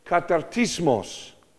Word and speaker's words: katartismos: The word for this phrase here in Greek is the word katartismos. I know I'm going really deep into the Greek, but hang in there. Is this interesting? He uses the word katartismos. Let me say it right. Katartismos.